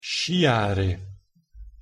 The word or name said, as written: sciare